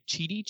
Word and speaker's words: Chidi: Dear Michael, Sean, Chidi, and Jason. I don't know if I said the name right. Chidi is right.